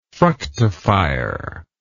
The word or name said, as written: fructifier